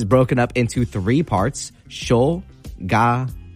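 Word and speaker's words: shoganai (しょうがない): I believe it's pronounced shoganai. Shoganai. Yeah, I said that pretty correctly. Again, if my pronunciation isn't perfect, we're all trying to learn as ambassadors, but I believe it is pronounced shoganai.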